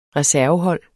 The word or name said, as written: reservehold